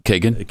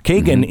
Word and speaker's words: Kagan: Kagan. – Kagan